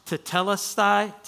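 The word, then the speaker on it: tetelestai (τετέλεσται): It's pronounced tetelestai, which literally is an accounting word.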